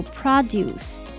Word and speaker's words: produce: 那它的发音也不一样，叫做 produce。